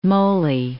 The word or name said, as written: Moley